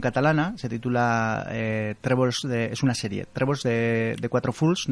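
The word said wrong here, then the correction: Trèbols de quatre fulles: no sé si lo he pronunciado bien en catalán. Trébols de Cuatro Fullas.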